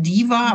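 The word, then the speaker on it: DHIVA: Da ist ein stummes H drin. Also das schreibt sich nämlich D. HIV-A spricht sich Diva, aber das H ist stumm.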